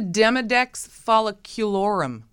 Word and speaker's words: Demodex folliculorum: It's a Demodex folliculorum.